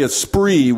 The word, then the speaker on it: esprit: And you call it the esprit, which is French for spirit, but it's spelled with an E, esprit.